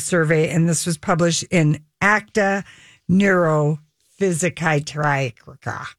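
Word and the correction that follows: Neuropsychiatrica: Neuropsychiatrika. – Oh. – ACTA. – Neuropsychiatrika.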